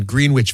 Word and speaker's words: Greenwich: I don't know, but that's how I said it when I moved here. The Greenwich Village. – Exactly. The little boy from Erie didn't know how to say Greenwich.